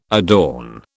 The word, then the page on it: adorn: British English